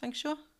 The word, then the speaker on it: feng shui: And this comes from the, is it pronounced feng shui? – Feng shui.